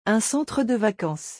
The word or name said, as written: un centre de vacances